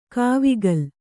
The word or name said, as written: ಕಾವಕೂಲ